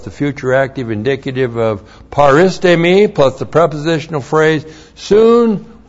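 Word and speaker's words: paristemi (παρίστημι): And then the verb paristemi. That's P-A-R-I-S-T. Long E-M-I. Paristemi.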